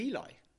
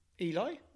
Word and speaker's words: Eli: a guy whose name is spelt E-L-I. So I thought he was Eli, but he wasn't. He was Eli. He corrected me. He said, Eli? Why did you say Eli? It's Eli.